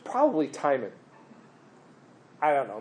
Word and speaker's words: Timon: Timon. – Okay, you. And you know why you guys pronounce it Timon? – Yes. Yeah. – Hakuna Matata, baby.